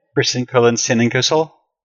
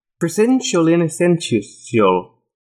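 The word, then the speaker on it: Prisencolinensinainciusol: No, but it's Italian. Those should be the soft C's. – What's a soft C? – Presentiolinescentiol.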